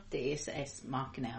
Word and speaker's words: Deus Ex Machina: Deus Ex Machina? – Deus Ex Machina, which I guess is Latin.